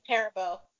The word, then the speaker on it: Perabo: Perabo.